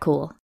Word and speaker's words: Kuhl: I'm Kat Kuhl,